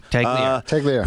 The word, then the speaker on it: Tagliere: Did I say that right? Tagliere? – You did. – Tagliere.